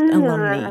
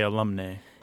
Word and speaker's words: alumnae: If they're only female graduates, then you want the feminine ending, which is AE and is pronounced alumni. Okay. – Alumni, not alumnae, right? – Right. – People do say alumnae. – Yeah, yeah, it's mainly alumni.